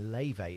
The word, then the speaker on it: Laufey: And this is a new song called Mad Woman by Lei Vei. It's really good. It's spelled L-A-U-F-E-Y, if you're looking her up, by the way.